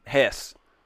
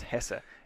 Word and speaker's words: Hesse: Shout out to all our folks in Hesse. I learned it's pronounced Hesse. – Hesse. – It's not pronounced Hesse. It's Hesse. Hesse.